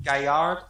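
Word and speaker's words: Galliard: Never mind how it's spelled. It is pronounced Gaillard. It's a French word.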